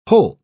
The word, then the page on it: holt: British English